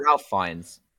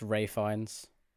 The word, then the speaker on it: Ralph Fiennes: Ralph Fiennes. – It's pronounced Ray Fiennes.